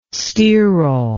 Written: sterol

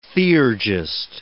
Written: theurgist

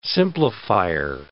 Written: simplifier

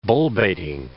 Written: bullbaiting

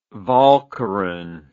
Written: walcheren

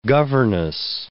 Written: governess

